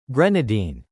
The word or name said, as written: grenadine